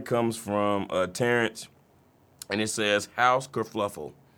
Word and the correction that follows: kerfuffle: K-E-R-F-U-F-L-E, House Kerfuffle, all right? – House Kerfuffle.